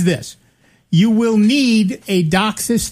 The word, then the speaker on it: DOCSIS: DOCSIS, yeah.